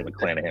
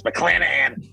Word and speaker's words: McClanahan: And that's how you say it. You can't say McClanahan. It's McClanahan! 9-3 with a 1.74 ERA.